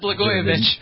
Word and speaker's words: Blagojevich: Blagojevich.